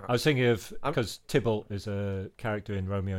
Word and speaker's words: Thibault: Is it Thibault? – Thibault. – Okay. This is Thibault, Drama. There we go. That was Thibault. – Thibault. – Is that right? – Thibault.